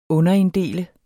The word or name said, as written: underinddele